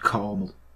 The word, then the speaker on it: Carmel: Carmel. Now, Americans, it's spelled C-A-R-M-E-L, Carmel, right? Not caramel, which you call Carmel, because you can't speak properly.